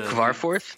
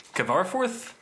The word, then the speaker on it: Kvarforth: Well, you and I, we were on Twitter a couple weeks ago, and we caught wind that Nicholas Craverth. I don't know how to pronounce his name. How do you pronounce the Kvarforth? – Kvarforth. – Yeah, sure.